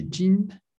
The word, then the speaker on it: jeans: OK, so here the S, of course, is silent. Les jeans.